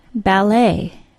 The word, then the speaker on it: ballet: Then we have the silent T of the word ballet, B-A-L-L-E-T. This word doesn't necessarily look out of place when you see it written on the page, but its pronunciation often surprises learners.